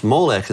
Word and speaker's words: Molech: But the way that it's pronounced, Molech, is probably goes back to the word Boshet, which means shame.